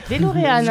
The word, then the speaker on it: DeLorean: C'est DeLorean, hein.